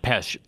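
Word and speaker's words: Pêche: How do you spell it? – P-E-C-H-E. – Okay. – Pesh.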